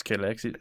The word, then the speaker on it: Cadillac: How do they actually pronounce Cadillac? – Cadillac. It's Cadillac. Cadillac.